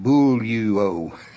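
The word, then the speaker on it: bull-you-o (βουλεύω): You pronounce it, according to them, bull-you-o.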